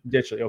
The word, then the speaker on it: Ditchley: and that is Ditchley. – Yeah. – Out of the, is it, I don't know what it is. I don't even know if that's how you pronounce it, but I guess that is how you pronounce it.